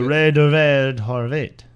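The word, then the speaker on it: Laurent Duvernay-Tardif: Laurent Duvernay Tardif.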